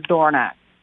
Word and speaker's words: Dorignac: The G is silent. So how we've always said it is Dorignac.